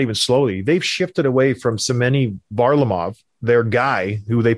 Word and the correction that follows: Semyon: Did I butcher the name? – Semyon Varlamov.